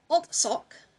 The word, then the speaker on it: Alzog: Okay, and number three, I said Alzog. It's, again, this is where it's spelled differently than it's pronounced. It's Altsok, Johannes Baptist, or Johannes Baptist Altsok.